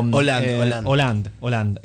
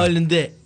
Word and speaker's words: Hollande: Estamos hablando de una reforma laboral que el gobierno de Hollande quiere, Hollande, no sé cómo se dice, no soy francés, ni estudié nunca francés, pero... – Hollande. – Que vino hace poco acá. – Hollande.